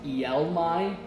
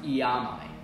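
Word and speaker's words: iaomai: I think I stated when we did 1 Peter 2 that I thought it was pronounced ielmai with an L, but it's pronounced iamai for the Greek.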